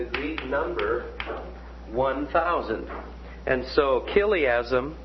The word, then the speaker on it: chiliasm: And this is spelled C-H-I-L-I-A-S-M, and it's pronounced chiliasm.